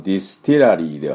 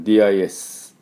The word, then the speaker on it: distillery: で、まあ当然ですけど、このディスティラリーでは通じませんね。で、まあ、スペルはですね、 DISTILLERY ですね。ね、ですから、どんな発音になるかというと、 distillery distillery っていう音になります。 distillery ですね。で、これ distill の時は till としっかり言うんですが、 distillery になると、半母音になりますね。